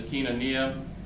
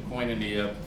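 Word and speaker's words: koinonia (κοινωνία): Well, in Greek, the word communion is koinonia. You may have heard the Erasmian pronunciation, koinonia.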